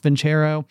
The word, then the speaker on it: Vincero: I believe it's supposed to be pronounced Vincero, but